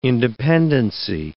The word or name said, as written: independency